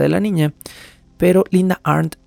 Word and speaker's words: Arndt: Esto se sumó con una acusación de parte de la detective Linda Arndt, a quien mencionamos en el episodio anterior, Linda Arndt, en caso de que tengan dudas de cómo estoy pronunciando el apellido, es A-R-D-N-T, Arndt, con una T final, Arndt,